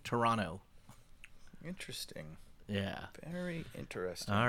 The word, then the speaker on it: Toronto: Where they don't pronounce the T in Toronto? – How do they say it?